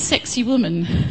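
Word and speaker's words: Sacsayhuamán: And it's actually, it's pronounced sexy woman. That's not what it looks like, but that's how they say it.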